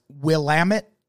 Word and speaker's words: Willamette: And I know for a fact it's pronounced Willamette and not Willamette because I was hmm. in Oregon once and I got corrected by a fucking local Oh yeah, don't you hate that?